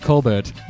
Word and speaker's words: Colbert: And we're going to be looking at it's pronounced Colbert.